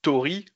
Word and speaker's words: Tauri: Moi, bah, par exemple, si on dit Tori, comme on disait tout à l'heure, c'est la technologie de Murmure. Tori, ça s'écrit Tauri.